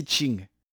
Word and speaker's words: Qing: Qing. En effet, le lion vert en chinois Qing, se prononce exactement de la même manière que la dynastie Manchu en question,